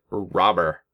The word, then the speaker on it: Robert: Dude, I found out the other day that Luis Robert, Sox Cuban guy, he pronounces it robber, like a thief. Luis Robber. Just no T.